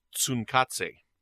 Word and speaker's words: Tsunkatse: and if I can correct you on something based on what you said the last episode, it's pronounced Tsunkatse.